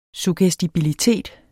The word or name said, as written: suggestibilitet